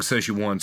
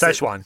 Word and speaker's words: Szechuan: We'll bring along Szechuan sauce too. – God. – This. Oh my God! I can't even pronounce Szechuan.